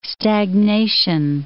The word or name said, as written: stagnation